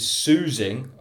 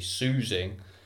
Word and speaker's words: Tzusing: This is Soozing. I think I've pronounced that right. T-Z-U-S-I-N-G, Soozing.